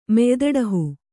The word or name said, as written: ಮೆಯ್ದಡಹು